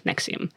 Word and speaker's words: NXIVM: It's not spelled like that. N-X-I-V-M, but it's pronounced Nexium.